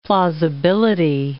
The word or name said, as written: plausibility